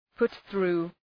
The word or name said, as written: put through